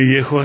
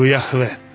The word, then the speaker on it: Jahve: Prije svega Bogu nije ime Jehova, nego Jahve. To je pogrešno čitanje jevrejskog imena Božijeg.